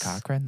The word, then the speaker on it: Cochran: It was written by John Cochran. I think that's how his name is pronounced. And directed by Bob Suarez. – John Cochran, like Cochran, like the great grandfather to Zefram Cochran.